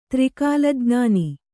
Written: ತ್ರಿಕಾಲಜ್ಞಾನ

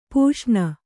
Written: ಪೋಷಣ

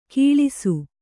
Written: ಕೀಳಿಸು

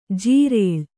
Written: ಜಿರಳೆ

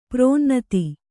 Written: ಪ್ರಾಣನಾಥ

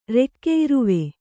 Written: ರೆಕ್ಕೆಇರುವೆ

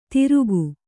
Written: ತಿರುಕು

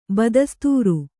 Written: ಬದಸ್ತೂರ

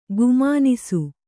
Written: ಗುಮಾನಿಸು

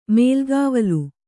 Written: ಮೇಲ್ಗಾವಲು

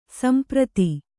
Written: ಸಂಪ್ರೀತ

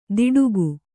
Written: ದಿಡುಗು

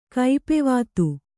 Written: ಕೈಪೆವಾತು

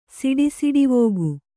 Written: ಸಿಡಿಸಿಡಿವೋಗು

